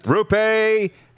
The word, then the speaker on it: Roope: Is it Roope or Roopy? – I want to say Roope, isn't it? – Roope Hintz.